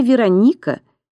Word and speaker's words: Вероника: «Имя Вероника произносится с ударением на «о», — поправил меня Славик. «От города Верона».